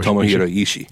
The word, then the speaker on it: Ishii: Orange Cassidy and Ishii. Is that how you pronounce his last name? – Yes. – Tomohiro Ishii.